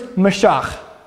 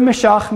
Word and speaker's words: mashach: The Hebrew is Mashach. Okay? The verb mashach means to dump oil on something or somebody. That would be spelled with the three Hebrew letters mem, shin, and chet, mashach.